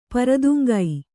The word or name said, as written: ಪ್ರೋತ್ತುಂಗ